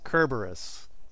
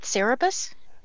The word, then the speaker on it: Cerberus: So, well, I agree, because they keep saying Cerberus is pronounced Cerberus, and I know it's pronounced Kerberus. – Wait, it's not Cerebus? – It's not Cerebus. It's Kerberus.